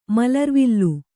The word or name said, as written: ಮಲರ್ವಿಲ್ಲು